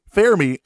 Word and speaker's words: Fermi: And you said it right. Fermi. I say Fermi all the time.